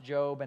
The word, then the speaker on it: Job: In fact, not just that, but the very first book of the Bible ever written was a book named Job, and it's spelled like Job, but it's pronounced Job, and I don't get it, but that's just kind of the way it is.